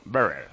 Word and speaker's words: Berrer: A primeras de cambio contra Berrer, un alemán, Berrer, se pronuncia.